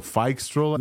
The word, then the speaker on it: Phixtral: So Phixtral is the way to pronounce this.